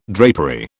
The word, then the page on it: drapery: British English